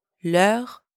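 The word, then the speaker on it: leurs: But if the noun is plural, you use leur with a silent S at the end. Par exemple, leur parents, their parents, leur parents.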